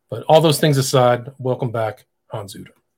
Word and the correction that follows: Utter: It's actually Uter. You have to Uter.